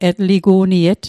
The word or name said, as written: etligu'niet